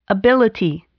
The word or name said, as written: ability